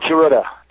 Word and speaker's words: Tsuruta: Did I pronounce that right, Tsuruta? – Yeah, Jumbo Tsuruta. – Tsuruta, okay. – And, I had left the AWA.